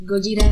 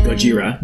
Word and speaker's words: Gojira: Say it right. Gojira. – Gojira. – Gojira.